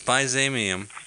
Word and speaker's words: byzanium: No. It's byzanium. – Like the empire, right? The Byzantine.